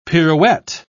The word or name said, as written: pirouette